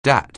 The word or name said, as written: DAT